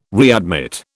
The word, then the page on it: readmit: British English